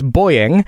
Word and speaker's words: buoying: We have Dean from England who writes, Sam is correct that we pronounce buoying as boying.